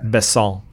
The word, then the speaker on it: Besson: Yeah, I had to get the pronunciation correct. I like him a lot. – Besson.